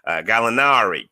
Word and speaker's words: Gallinari: You got to kind of say it like with an Italian twang on it. Gallinari, Chris Dunn, and Rajon Rondo.